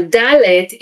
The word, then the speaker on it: Dalet: While Dalet is now almost universally pronounced like a normal English D, in the past, it was not always this way.